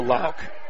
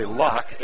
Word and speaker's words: Lauck: Feeds it to Lauk, or I should say Lock, L-A-U-C-K, pronounced Jacob Lock.